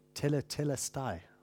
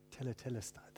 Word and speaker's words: Tetelestai: When Jesus said, "It is finished," how do you say it in the Greek? Tetelestai. Tetelestai.